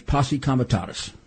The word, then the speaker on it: posse comitatus: I'll give you the usual lawyer's pronunciation, which I think is the proper Latin. Not always lawyers, you know, mess up Latin because most lawyers are big dopes, as you know. It's posse comitatus.